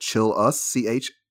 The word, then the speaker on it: Chillus: Yeah, and you can find me on Twitter at ChillUs, CHILL_US. That's how my last name is pronounced.